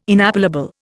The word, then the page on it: inappellable: Indian